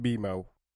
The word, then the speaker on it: BMO: It's pronounced BMO.